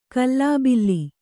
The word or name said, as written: ಗಲಿಬಿಲಿ